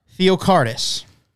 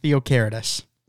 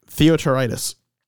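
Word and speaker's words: Theocharidis: We got Tim Theocaritis. Caritis. There we go. Theocaritis. – Tim Theotaritis. – No, it's not. – Yes, it is. – No, it's not. – Yes, it is. You got letters. You're allowed to pronounce it. You're allowed to say the H.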